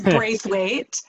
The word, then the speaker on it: Braithwaite: It's Braithwaite.